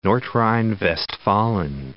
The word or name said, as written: Nordrhein-Westfalen